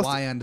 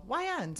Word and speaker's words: Wiand: This is Cole Wynoski, you said your last name? Wynoski? – I didn't pay attention to the pronunciation. – Wyand. – Okay. Like, why end this call? – Why? – Oh, why end? – Do you say that to clients?